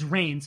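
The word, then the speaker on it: Rahne: Logan actually says first rain. He pronounced it right, as opposed to Ronnie in the episode Retreat. Do you remember that? – Oh, I think I do. – Yeah. But Logan, in this episode, says rain, so they actually pronounced it right this time.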